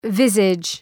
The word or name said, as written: visage